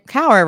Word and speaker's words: Kauer: It's funny because I always say cower power, or I'll say cower rhymes with flower.